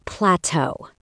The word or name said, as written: plateau